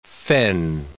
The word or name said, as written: Fenn